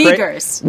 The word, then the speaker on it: Uyghurs: or how do you say it? Is it Uyghurs and internment camps and everything that we know about it? But then again, we're the Uyghurs. – Uyghurs. Yeah.